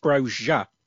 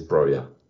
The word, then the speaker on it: Broja: I think it's pronounced Broja. – I did have a look. I've been in the off season. I've been learning a bit of Albanian, and of course you have. I mean, why wouldn't you? – What else are you going to do with that weekend off? And it turns out it is Broja.